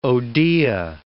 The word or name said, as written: odea